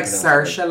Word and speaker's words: Saoirse: It's like Saoirse, like inertia. Yeah. – Like inertia. – That's what she says in interviews. – She changes it all the time. Saoirse, like, who the fuck have you ever heard inertia? – Yeah.